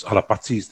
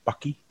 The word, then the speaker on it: Alapati: So obviously it's spelled A-L-A-P-A-T-I, so Alapati. Whereas obviously you hear a lot of people say Paki, so the T becomes a K. So in the Samoan, like in the Pat Samoan we call it, if there's a T, word T, and you pronounce a K, that's actually it's like the formal way of saying it.